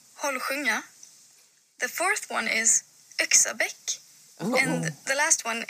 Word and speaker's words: Yxabäck: I would say that as Axibak.